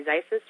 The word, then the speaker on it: Zisis: And then for my last name, I say Zisis rhymes with Vices, and I almost always spell it and don't expect people to remember it.